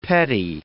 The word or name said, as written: petty